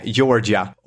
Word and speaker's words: Georgia: Är det så du uttalar här? – Jag ska ju till sydstaterna i sommar, så att nej, Georgia.